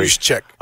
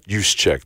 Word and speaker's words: Juszczyk: Juszczyk, is that how you say it? Tight end George Kittle, running back Christian McCaffrey. – Juszczyk. – Juszczyk, thank you very much, because it's spelled just wildly.